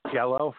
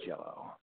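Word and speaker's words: giallo: I think they're pronounced Jello films, G-I-A-L-L-O. I think it's pronounced Jello.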